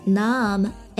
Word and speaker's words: numb: 然后呢，我完全变得 numb, N-U-M-B，这里的词尾的 B 是不发音的，那这个词呢一般用作形容词，意思是麻木的，失去知觉的 numb。